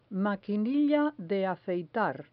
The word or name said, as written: maquinilla de afeitar